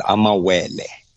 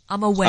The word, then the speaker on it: amawele: The word for today, the Xhosa word for today is amawele. – Amawele. – Amawele means amawele. Yeah. It means twins. Amawele is spelled A-M-A-W-E-L-E.